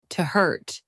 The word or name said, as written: to hurt